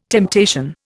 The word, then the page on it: temptation: Indian